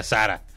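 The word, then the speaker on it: Zara: Bueno, este popular video de la, esta morra influencer que dice, no se dice Sara, se dice Sara. Sara es una marca española. Se llama Sara, se pronuncia Sara.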